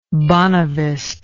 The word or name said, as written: bonavist